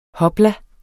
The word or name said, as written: hopla